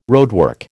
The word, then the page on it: roadwork: American English